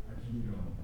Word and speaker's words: Aikido: Aikido. – Aikido.